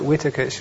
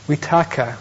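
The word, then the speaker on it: vitakka: I call it vitaka, my pronunciation has gone off. It's vitaka, thought.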